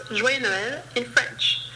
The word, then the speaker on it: joyeux: So for the first word, joyeux, there is two syllables. The first one is joie, and the second one is yeux.